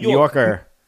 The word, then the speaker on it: New Yorker: How do you say it? It's New York. – New Yorker. – New Yorker. – Oh, yeah. You kind of like blend the middle.